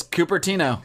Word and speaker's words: Cupertino: That is, Cupertino.